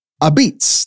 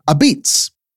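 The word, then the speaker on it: apizza: A beats, that's how you say it. A beats.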